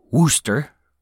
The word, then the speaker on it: Worcester: Just kidding, I know that it's pronounced Wooster.